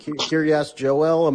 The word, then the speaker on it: Kiryas Joel: Kiryas Joel.